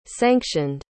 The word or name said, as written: sanctioned